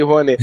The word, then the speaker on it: Rouanet: Lei Rouanet.